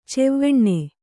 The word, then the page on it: ಛಾವನಿ: chāvani